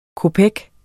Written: kopek

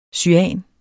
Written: cyan

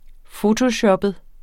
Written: fotoshoppet

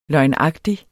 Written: løgnagtig

